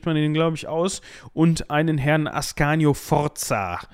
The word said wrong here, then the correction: Sforza: Äh, Sforza, so muss man ihn aussprechen.